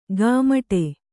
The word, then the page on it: ಗಾಮಟೆ: gāmaṭe